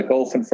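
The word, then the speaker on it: Golphin: And so just for the sake of the listeners, when you say Golfin, it's not G-O-L-F-I-N-G. Can you spell it for the customer or for the listeners? – So it's G-O-L-P-H-I-N.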